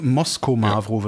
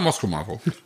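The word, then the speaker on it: Moschomavro: Gruß aus dem Chat. Mosho Mavro wird es ausgesprochen. – Ah, Mosho Mavro. Alles klar. Danke.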